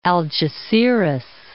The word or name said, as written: Algeciras